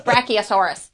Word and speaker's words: Brachiosaurus: Being like, it's pronounced Brachiosaurus. Stop it.